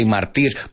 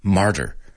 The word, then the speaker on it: martyr: You say martir, vamos a pronunciarlo en inglés, martyr. A martyr, okay?